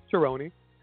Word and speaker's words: Cerrone: And by the way, the correct pronunciation to the last name, Cerrone. All right, so let's 33-year-old.